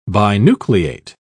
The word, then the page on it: binucleate: British English